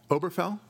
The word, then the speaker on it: Obergefell: Okay, I think there's a G in there somewhere, but you don't pronounce it? – There was a baseball player named Ken Obergefell many years ago. – Okay. – And this is that same name with a G instead of a K, Obergefell.